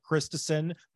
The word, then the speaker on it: Christison: Andrew Christison, my last name is spelled like Christ is on, a little bit different than Eric's.